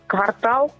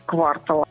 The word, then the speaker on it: квартал: Это известные слова, там, допустим, квартал, в котором очень часто делают ошибку и произносят как квартал.